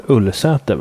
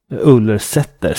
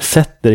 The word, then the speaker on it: Ullersäter: För nog måste det uttalas Ullersäter, va? Ullersäter. Sätter inte så vanligt ordled i svenska ortsnamn. – Nej, då skulle man uttala det sätter, så skulle det nästan vara ett dialektalt uttal av ett ortsnamn som ändå stavades med ett T. Så att Ullersäter måste ju vara det rimliga här, om även lokalbefolkningen säger det.